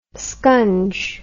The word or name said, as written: scunge